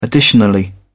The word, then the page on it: additionally: British English